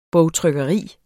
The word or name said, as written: bogtrykkeri